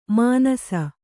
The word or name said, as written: ಮಾನಿಸ